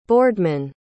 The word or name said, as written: boardman